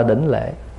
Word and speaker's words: đỉnh lễ: Đảnh lễ hay là đỉnh lễ cũng được. Người Bắc phát âm là đỉnh lễ: nhất tâm đỉnh lễ.